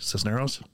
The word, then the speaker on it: Cisneros: He served as Columbia University Assistant Director of Admissions and Minority Recruitment, Special Assistant to Mayor Henry Cisneros of San Antonio, Texas, did I say that right? Cisneros? Mm-hmm. Yes.